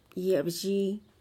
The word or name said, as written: ye'ebzhii